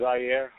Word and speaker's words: Zaire: Zari, like the country. – Okay. Go ahead and introduce yourself for us, Zaire. – My name is Zaire.